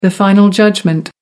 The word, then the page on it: final judgment: American English